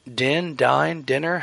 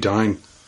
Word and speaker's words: Dyn: But anyways, Oracle has acquired Dinner. How do you say that? D-Y-N? – I think it must be Dine.